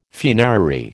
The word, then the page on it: funerary: American English